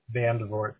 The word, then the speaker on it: Vandevort: Benjamin, B-E-N-J-A-M-I-N. – Perfect. – The middle initial, Vandevort, V-A-N-D-E-V-O-R-T. – Got it. And what's a good email address for you? – Ben, B-E-N, at Ben, B-E-N-S-C-R, .com.